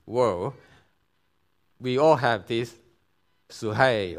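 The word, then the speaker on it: suhei (ψυχή): And Jesus says, "Whoever loves his life," that's suhei, I don't pronounce it as psyche, it's a Greek pronunciation, suhei.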